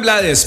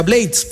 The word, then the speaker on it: Blades: Y aquí les boto el dato que por allá en una entrevista dijo el señor Rubencho que el apellido realmente se pronuncia Blades porque él es de origen inglés.